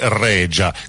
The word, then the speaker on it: Rexha: Il suo cognome si scrive Rexha con la X e la H, un po' come Anna Oxa, però la pronuncia è corretta, mi dicono essere Regia, così come sarebbe Oja, no?